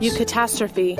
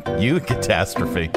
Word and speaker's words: Eucatastrophe: How do you pronounce this? – Eucatastrophe. – Eucatastrophe. – Eucatastrophe. – Eucatastrophe. – Yeah. – That's what you are. The opposite of catastrophe is eucatastrophe. Did I say it right? – Yeah.